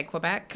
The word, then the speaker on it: Quebec: Is that how you pronounce it? Quebec? – Everybody's different, but yeah. I mean, I would say Quebec.